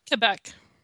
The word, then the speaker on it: Quebec: Quebec.